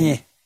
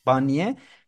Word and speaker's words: Barnier: Yeni başkan Barnier, doğru mu telaffuz ediyorum bilemedim. – Barney. – Barnier, yeni işte başbakan atanan Barnier'in programının açıklamasını bekliyoruz demiş.